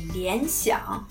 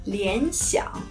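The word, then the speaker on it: Lianxiang (联想): Le nom chinois de la marque Lenovo est Lianxiang, qui veut dire littéralement faire penser à quelque chose. Lianxiang.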